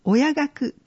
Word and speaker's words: 親学: 親を楽しむと書いて親学と読みます。未来親学講座は親としてのスキルアップや仲間づくりのため、子育てについて学ぶ場や交流を通して子育て世代を支援します。